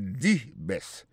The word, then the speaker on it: the: Alors si vous besoin mettre accent sur que monde c'est pi bon parmi pi bon, il pas dit the best, il dit di best. Au lieu de prononcer T H E A D qui veut dire le, comprenez? Vous prononcez di best.